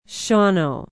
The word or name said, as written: shawano